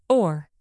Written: oar

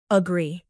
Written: agree

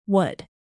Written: would

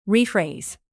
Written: rephrase

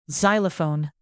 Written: xylophone

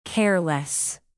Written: careless

